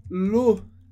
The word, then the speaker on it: l'eau: L'eau significa a água. Só esse som, l'eau, l'eau, porque as letras E, A, U sempre vai dar o som O.